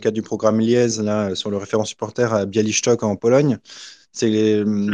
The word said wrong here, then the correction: Białystok: l'as mal prononcé, hein, hein, je te pardonne. Bah, vas-y, vas-y, vas-y, Mathieu Z. – Białystok.